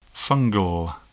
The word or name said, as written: fungal